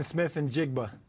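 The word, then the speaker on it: Smith-Njigba: Jackson Smith and Jigba.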